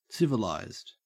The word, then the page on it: civilised: Australian English